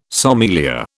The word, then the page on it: sommelier: British English